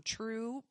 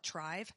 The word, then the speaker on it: TRVE: It's pronounced True. – So it looks like it's spelled T-R-V-E. – Yeah, we used to call it Trive, but that's it's true.